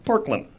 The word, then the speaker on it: Parkland: which all of Texas let me know is pronounced Parkland.